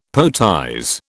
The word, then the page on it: poetize: British English